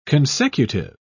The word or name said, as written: consecutive